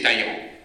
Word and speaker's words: Talleyrand: Talleyrand, on dit Talleyrand, mais paraît-il qu'il faut prononcer Talleyrand.